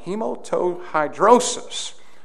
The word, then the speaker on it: hematohidrosis: The clinical term is hematohidrosis. Man, I did good on that. Hematohidrosis.